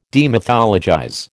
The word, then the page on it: demythologize: American English